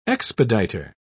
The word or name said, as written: expediter